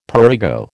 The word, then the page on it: prurigo: American English